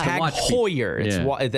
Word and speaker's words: Tag Heuer: Tag Heuer. – Right. – It's Tag Heuer.